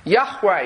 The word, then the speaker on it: Yahweh: Yahweh is probably the closest we can get to the original pronunciation.